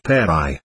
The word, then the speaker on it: Phagri: Dash south of Lhasa there is a village called Phagri, that name being pronounced Peri.